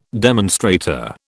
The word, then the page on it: demonstrator: British English